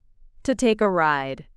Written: to take a ride

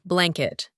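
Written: blanket

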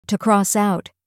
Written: to cross out

